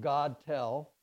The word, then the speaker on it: Gettle: One is Anthony Gettle. It's spelled like God Tell.